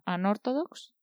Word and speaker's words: Unorthodox: Y bueno, una de las últimas series que he terminado es la de, no sé cómo se pronuncia, Unorthodox, ¿se pronuncia ah, así? – Sí.